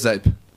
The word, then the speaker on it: Zepp: Zepp. – Zepp. That's what I said. Zip? – It's a weird Dutch pronunciation.